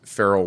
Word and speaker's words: Farrall: You can follow us on Twitter at Farrell Wealth. It's at F-A-R-R-A-L-L Wealth. I like to say it's pronounced like Will Farrell, but he spells his name miserably wrong. The correct way is F-A-R-R-A-L-L, and then W-E-A-L-T-H on Twitter.